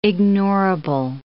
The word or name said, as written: ignorable